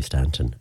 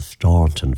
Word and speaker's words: Staunton: I have a feeling that that town may well have been named after somebody from County Mayo, because the traditional pronunciation of that name here in Mayo would be Stanton, a silent U, even though the more posh version of it would be the Staunton version of it, which a lot of RT folks and all of those like to use when they're talking about Cora.